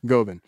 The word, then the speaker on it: Govan: Glasgow Govan. Did I say that right? – Uh-huh. – Okay, Glasgow Govan. – Not S. Govan. There you go.